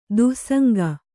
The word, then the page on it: ದುಸ್ಸಂಗ: dussaŋga